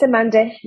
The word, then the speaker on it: Samande: No, it's Samande.